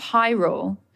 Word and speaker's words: Hyrule: The Kingdom of Hyrule is the main setting for which classic video game franchise? – The Kingdom of who? – Hyrule. – Hyrule. – But it's spelled H-Y-R-U-L-E. Hyrule.